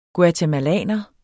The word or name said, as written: guatemalaner